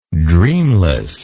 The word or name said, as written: dreamlessness